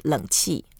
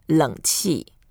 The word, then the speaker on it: 冷气: To say air conditioning in Chinese, we say 冷气. – 冷气. – 冷气. – 冷气. – 冷 means cold. It's a third tone, 冷.